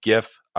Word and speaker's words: GIF: I pronounce it with a hard G. All you soft G GIF people out there, I don't know what a GIF is outside of peanut butter. It's GIF.